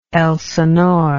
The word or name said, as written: elsinore